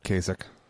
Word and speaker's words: Kasich: Kasich.